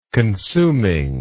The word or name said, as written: consuming